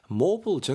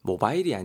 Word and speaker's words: mobile: 모블 정육점이 있더라니까? 모블. M-O-B-I-L인데요. 여러분, 모바일이 아니고요. 모블 정도로 발음하셔야 됩니다. 모바일은 약간 좀 우리 한국식 발음인 것 같아요.